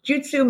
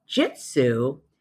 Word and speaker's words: jutsu: Now, jutsu means method. The common mispronunciation of jitsu actually means truth.